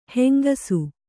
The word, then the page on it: ಹೆಂಗೂಸು: kannada